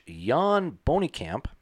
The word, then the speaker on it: Bonikamp: with RVV member, his name would be pronounced in Dutch, Jan Bonikamp.